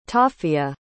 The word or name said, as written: taffia